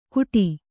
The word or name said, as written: ಘುಟಿ